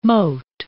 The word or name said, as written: mote